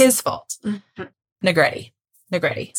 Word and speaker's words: Negrete: Negrete mm-hmm. Spaghetti. – Yep. – It rhymes with spaghetti.